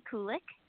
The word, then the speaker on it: Kulik: And I'm like, well, just think like calcium, then it's easy, you know, Kelsey Kulik.